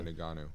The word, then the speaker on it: Ngannou: Ngannou or something? – Yeah, Ngannou.